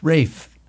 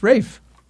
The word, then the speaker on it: Ralph: It's Rafe. It's Rafe. That's how you pronounce it. Check it. – What? I don't know if we're talking about the same person. – Ralph Fiennes. It's not Ralph Fiennes. It's Ralph Fiennes. That's how you say it. It's definitely Rafe.